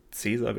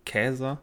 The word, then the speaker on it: Caesar: Also Caesar, wir sprechen es heute Caesar aus, äh, wird eigentlich gar nicht Caesar ausgesprochen, denn das lateinische C wird wie ein K gesprochen. Dann heißt es ja schon mal Käser